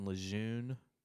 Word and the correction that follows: LeJeune: It's Lejean.